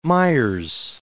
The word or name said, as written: Fort Myers